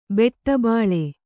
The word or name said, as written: ಬಟ್ಟಬೈಲು